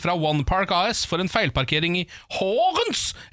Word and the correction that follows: Horsens: eller Horens eventuelt da, i Danmark. – Horsens. – Ja, det, her står det uten S. – Ja, jeg tror det uttales Horsens.